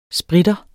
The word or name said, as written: spritter